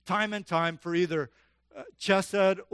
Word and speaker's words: chesed: Mercy, or the Hebrew is chesed, or chesed is how you hear me pronounce it.